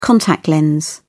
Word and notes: contact lens: British English

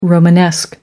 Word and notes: romanesque: American English

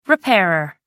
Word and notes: repairer: American English

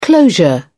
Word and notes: closure: British English

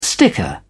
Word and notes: sticker: British English

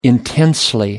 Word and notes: intensely: British English

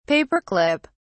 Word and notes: paperclip: British English

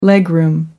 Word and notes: legroom: American English

